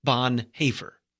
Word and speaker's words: Bonhoeffer: But if you look at the way it's spelled, the way Bonhoeffer spelled it, he spelled it Bonhoeffer, which is O with the two little dots over it, and that's pronounced with the A sound, Bonhoeffer. Now, I mean, technically it's pronounced saying the A with your mouth like an O, but that's so awkward on radio, I don't try it.